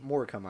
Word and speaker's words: Morecambe: Chelsea against Morecambe. Is that how you pronounce it? You tell me. – Morecambe, I believe, is how you pronounce it.